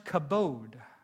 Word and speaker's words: kabod: It's pronounced kabod, and it means heavy weight or glory.